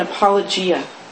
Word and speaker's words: apologia: an apologia.